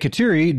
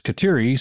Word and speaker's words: Kateri: The Mohawk version of Catherine is Kateri,